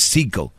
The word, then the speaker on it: Sicko: Vean un, documental de Michael Moore que se llama, ¿es Psycho o Psycho? Creo que es Psycho. Eh, se escribe S-I-C-K-O.